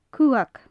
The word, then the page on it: cuthag: gaelic